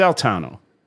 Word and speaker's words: Altano: So it's Altano. It's Italian.